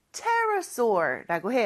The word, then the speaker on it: Pterosaur: 근데 왜 P 발음을 안 할까요? P는 무음입니다. 그래서 스펠링은 P로 시작하지만 발음은 Pterosaur라고 해요.